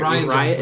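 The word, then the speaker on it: Rian Johnson: Abrams passed on what he had to I don't know if I pronounced his name right. – Ryan Johnson. – Is it Ryan Johnson? – Yeah. – I always want to say Rian. Ryan Johnson.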